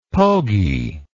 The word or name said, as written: pogey